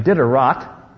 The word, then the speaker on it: Diderot: Diderot, or some people pronounce his name Diderot,